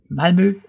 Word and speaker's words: Malmö: Also es ist nicht Malmö. Malmö ist deutsch und das klingt auch schon so ein bisschen hektisch, aber die Malmöbuana, die haben Zeit und ihre Stadt spricht sich Malmö aus, eben mit diesem etwas tiefen und dunklen Ö. Deshalb ist es Malmö. Das sind wirklich zwei Silben, die man bei der Aussprache auch hört.